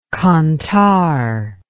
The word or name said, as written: kantar